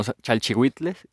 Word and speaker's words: chalchihuitl: chalchihuitl. Sí, como chalchihuitles, chalchihuitles, oh idiota, chalchihuites. Ah, por, por ahí va la pronunciación, chalchihuitl, ¿no?